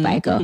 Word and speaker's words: Biko: I always say his last name wrong, Biko or Biko, I think mm-hmm. it's Biko.